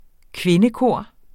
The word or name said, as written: kvindekor